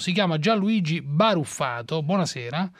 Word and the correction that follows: Burrafato: No, veramente è Burrafato. – Burrafato.